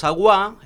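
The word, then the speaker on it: Awá: Y los Aguá, en realidad es con acento en la última, que son una tribu de pueblos originarios que viven en Ecuador y que están en peligro de extinción, ¿no?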